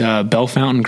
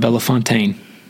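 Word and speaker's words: Bellefontaine: Our church is Bell Fountain Grace Church, not Bella Fontaine. Bell Fountain. So just don't ever get it mixed up. They don't like that. – It's too different? – They just pronounce it weird.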